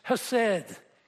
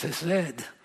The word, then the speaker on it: hesed: The Lord will command his loving kindness, hesed. Could never learn to speak it with the Hebrew roll in your throat, H, but it's hesed.